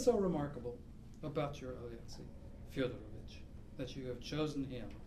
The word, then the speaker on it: Fyodorovich: Namely, although I call Alexei Fyodorovich my hero," is it Fyodorovich? – First syllable. – Okay. – Fyodorovich.